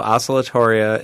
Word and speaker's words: Oscillatoria: Okay, so what is Oscillatoria algae, and am I pronouncing that correctly? – Yes. So Oscillatoria is a kind of blue-green algae, a kind of cyanobacteria, similar to Microcystis, in that it's a cyanobacteria, but it's different.